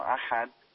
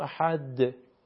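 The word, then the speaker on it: أحد: ببینید یک قاعده ما داریم به اسم قاعده قلقله، که یکی از حروفی که قلقله شامل حالش میشه حرف دال هست. در سوره توحید قل هو الله احد، یعنی شما وقتی میخواین حرف دال که ساکن هست تلفظ بکنین، به اصطلاح میگن که یک حرکت مختصری بهش میدین، یعنی حرکت مختصر.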